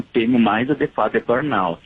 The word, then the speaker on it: burnout: Burnout seria uma pronúncia mais afrancesada.